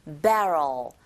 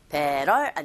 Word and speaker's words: barrel: 배럴 아니고 barrel, barrel. 끝발음이 L 소리로 끝났으니까 혀끝이 위에, 윗니 뒤에 붙는다는 거 요거 기억하셔야 되고요.